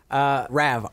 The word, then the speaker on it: Rav: Rav, R-A-V. That's my new real name. – Like the car, like the Rav4. – Just like that.